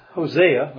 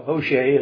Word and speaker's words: Hosea: He's quoting out of Hosea, or Hosea as some pronounce it.